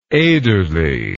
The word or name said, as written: Ederle